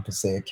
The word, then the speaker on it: Passaic: Passaic County Technical Institute, Wayne. – So good pronunciation of Passaic.